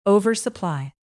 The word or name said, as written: oversupply